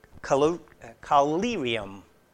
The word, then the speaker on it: collyrium: And the most famous was a product called collyrium. Yeah, I said it right. Collyrium. Depends which syllable I assent. But collyrium.